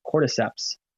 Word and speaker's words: cordyceps: There's an S at the end. It sounds pluralized. It's very strange, but it's pronounced cordyceps.